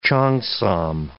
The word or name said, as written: cheongsam